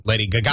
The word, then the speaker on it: Gaga: Lady Gaga. – Gaga. I like Lady Gaga. – I do too. I like the emphasis on the last syllable.